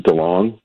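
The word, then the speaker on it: DeLonge: is it DeLong? Is that how it's pronounced? – Yeah.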